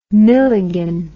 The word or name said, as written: milligan